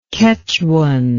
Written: kechuan

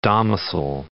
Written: domicil

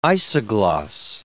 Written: isogloss

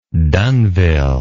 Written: dunnville

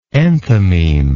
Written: enthymematical

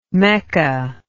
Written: mekka

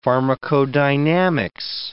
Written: pharmacodynamics

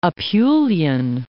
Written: Apulian

